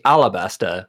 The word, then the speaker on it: Alabasta: Like I oftentimes see it spelled Arabasta, but they say Alabasta with like an L sound.